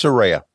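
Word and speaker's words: Saraya: boy, I always say it wrong. Is it Soraya? Cause I don't think I was saying Soraya before. It's Soraya. Yeah.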